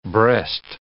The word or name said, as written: breast